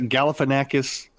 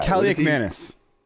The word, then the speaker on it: Kaliakmanis: I can't – what's the quarterback? Galifianakis? Some Greek guy. – Kaliakmanis.